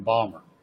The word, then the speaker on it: Baltimore: Is that actually pronounced Baltimore? Because a few people I've met from there tell me they're from Balmer.